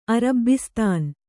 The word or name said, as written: ಅರಬ್ಬಿಸ್ತಾನ್